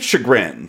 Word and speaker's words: chagrin: That how you pronounce that word? – I think it's chagrin. – Chagrin? – Yeah, no, yeah. I think I pronounced it wrong.